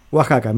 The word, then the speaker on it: Oaxaca: Que Mari Carmen desde Oaxaca, así se pronuncia, ¿no es cierto? Oaxaca, me pronuncia bien.